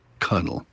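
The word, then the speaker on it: colonel: And for some reason, the R stuck around in the pronunciation, even though there's no R in the spelling. And in early English, in fact, in some southern states, it's still pronounced cunnel.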